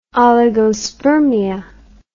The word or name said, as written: oligospermia